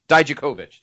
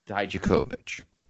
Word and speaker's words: Dijakovic: Is it Dijakovic? Dijakovic. That's it. Dijakovic.